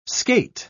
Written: skate

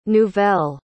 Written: nouvel

